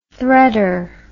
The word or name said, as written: threader